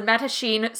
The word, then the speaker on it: Mattachine: It is the Mattachine Society.